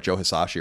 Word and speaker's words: Joe Hisaishi: So I have to say that this particular composer, I think I'm saying his name right, Joe Hisaishi. – You're saying it right.